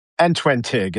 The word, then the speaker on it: Antwentig: So Mike and I did this, and Antwentin, did I say that right? – Antwentig. It's the Old English Antwentig. For 21. Antwentig, yes.